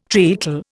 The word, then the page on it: treacle: Indian